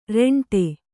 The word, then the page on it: ರೆಂಟೆ: reṇṭe